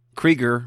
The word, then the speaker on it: Krieger: Yeah, I was listening to Geek Brunch Retrocast because Rob Krieger rhymes with Seeger.